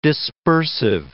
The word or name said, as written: dispersive